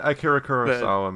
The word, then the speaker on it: Kurosawa: It's like the scene in Star Wars where they watch all those Kurosawa, is that how you pronounce it, films? – Yeah, Akira Kurosawa movies.